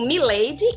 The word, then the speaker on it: Mileide: É assim, eu costumo dizer que eu sou única, porque o meu nome se escreve mesmo Mileide, em português, né? M-I-L-E-I-D-E, e Rebelo.